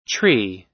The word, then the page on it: tree: American English